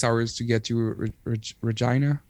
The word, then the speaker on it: Regina: I personally always said Regina because in French you say Regina. But when I heard that in English it's Regina. – Regina.